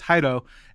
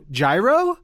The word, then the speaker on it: Jairo: Yeah, I should probably use my father's name, which is Jairo, and which is spelled J A I R O. And every white guy ever has always said, "Oh, Gyro."